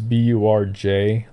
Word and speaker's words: Burj: I can never figure out how to pronounce it. I know it's Burj, the Burj Dubai, like the world's tallest tower.